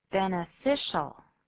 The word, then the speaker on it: beneficial: Beneficial. Beneficial. 注意这个 I 的发音是 I, 不是 E, 不是 beneficial. 另外注意 CI 这个写法要念作是的音 beneficial. Beneficial.